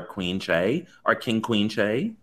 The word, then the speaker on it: Che: I would love to see Aiden interact with Shay. Or Che. – Shay Mitchell? – Che Diaz. I said Shay first.